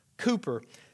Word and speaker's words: Cowper: Now, it looks like Cowper, C-O-W-P-E-R, but it's actually pronounced Cooper.